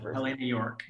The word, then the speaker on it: Helene York: But that's like, and by the way, the Brooke character, has Helene, how do you pronounce it? Helene York. Yeah. – Helene York.